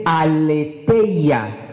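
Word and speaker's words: aletheia (ἀλήθεια): Verdad en griego se dice aletheia. ¿Cómo se dice? Aletheia.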